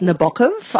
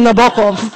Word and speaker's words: Nabokov: Actually, it's Nabokov. I don't know if you... You've got to put the emphasis on the second syllable.